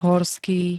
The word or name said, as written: horský